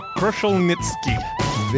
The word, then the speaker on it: Kruszelnicki: It's Dr. Karl Kruszelnicki.